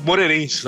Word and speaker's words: Moreirense: E lá em Portugal, esse ano começou bem lá pro glorioso Moireirense. É, é, você tem que até dar uma pausa para pronunciar o, o, nome do Moireirense para no Moreirense, sabe? No, no, no engolir alguma letra.